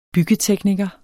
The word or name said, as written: byggetekniker